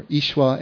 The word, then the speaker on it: Yeshua: When a group of men lower their paralyzed friend down through the roof to Yeshua in Matthew 9, Yeshua, or Ishwa as it would actually be pronounced, grants their request for healing by saying, take courage, son, your sins are forgiven.